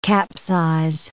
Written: capsize

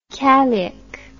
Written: cailleach

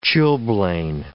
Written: chilblain

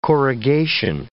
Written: corrugation

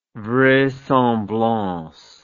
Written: vraisemblance

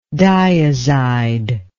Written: Dyazide

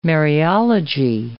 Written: Mariology

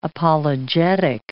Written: apologetic

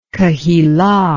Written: kehillah

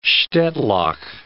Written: shtetlach

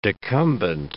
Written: decumbent

